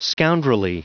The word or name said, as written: scoundrelly